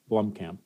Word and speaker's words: Blomkamp: Blomkamp.